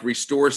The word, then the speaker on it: ReStore: I'd never really ever heard of an actual ReStore. And I'm saying it right, right? It's like a ReStore. It's not like restore something. It's a ReStore.